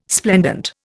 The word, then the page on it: splendent: Indian